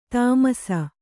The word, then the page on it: ಥಮಾಸ: kannada